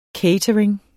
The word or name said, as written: catering